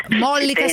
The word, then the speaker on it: Mollica: E ne parliamo con l'avvocato Lucrezia Mollica. Buongiorno, benvenuta. – Sì, buongiorno, buongiorno a lei. Mollica è il mio cognome. – Mollica, scusi, l'accento sulla O, sì.